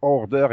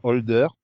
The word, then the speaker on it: Order: Oui, mais c'est Order et pas Holder.